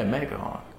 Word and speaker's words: McMahon: Am I pronouncing his last name wrong? – Who's, who? – Is it Vince McMahon? – Yeah, McMahon.